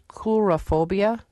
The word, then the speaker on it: coulrophobia: So did I pronounce the name of the fear right, coulrophobia? – You did pronounce it correctly.